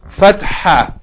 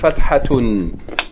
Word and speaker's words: fatha: It's spelled here F-A-T-A-H. It should be spelled F-A-T-H-A-H. Fatha. Okay, Fatha. And this way of writing it here throws off the pronunciation. So be careful, huh? It's Fatha. Fatha. Okay, so this is the first one. It's the sound of A, Fatha. Fatha. Or we can say, if we pronounce the last letter of the Tarmabutta, Fathatun. Fathatun. But we say, if we don't pronounce the ending, we say Fatha.